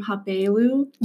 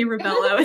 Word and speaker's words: Rabelo: My husband is from Brazil. So you actually pronounce my last name Rabelo. – You would never guess that, ever. – Yes, for us Americans, you can say Rabelo. It's just easier.